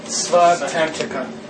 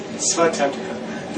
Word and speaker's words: Svatantrika: And our teacher pronounces them both pretty much the same way we are. Svatantrika.